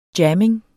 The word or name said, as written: jamming